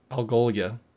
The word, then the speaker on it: Algolia: How do you say it? – Algolia. – Is it Algolia? – Algoria? – Algoria? – No, Al Gore. – No. Okay. There's an L in there.